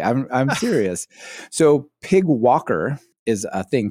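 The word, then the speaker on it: PyGWalker: PYG walker, and you might be saying, come on, pig walkers. This is, I know. It says right here, pronounced pig walker, just because you can and it's fun.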